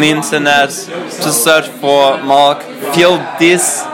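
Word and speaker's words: Marc: Mark.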